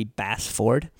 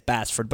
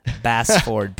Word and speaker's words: Basford: If we were actually going to pronounce it perfectly every time, it'd be Bassford. – Good, because that sounds a little too pretentious, like I'm trying to. So we just say it quick and easy, and it's Bassford. But on the radio and for Twitter purposes, I like to clarify Ryan, R-Y-A-N, Bassford, B-A-S-F-O-R-D.